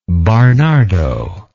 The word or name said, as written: Barnardo